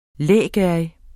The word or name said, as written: lægerrig